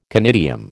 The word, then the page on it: conidium: American English